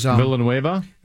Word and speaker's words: Villanueva: He's Villanueva.